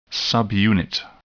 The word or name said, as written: subunit